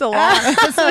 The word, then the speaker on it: Alanis: Like her name being Alanis. I don't know why. It's like Alanis. – oh, did I say it wrong? – No, it's Alanis. It's just like correct.